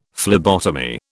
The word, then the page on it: phlebotomy: British English